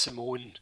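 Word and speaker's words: Simón: I've written your Simon, it's pronounced Simone by the Spanish.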